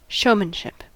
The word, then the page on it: showmanship: American English